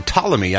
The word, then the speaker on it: Ptolemy: Spelled P-T-O-L-E-M-Y. – F her so much. – The P is silent. Ptolemy. Who, of course, was a famous Greek, Roman, Egyptian astronomer and mathematician.